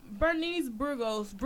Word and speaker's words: Bernice Burgos: All right, so Bernice Burgos, yeah, that's how you pronounce it.